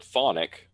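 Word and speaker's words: chthonic: In English, the word thonic, which they don't pronounce the CH in English when you talk about that, the word thonic in English means underworld.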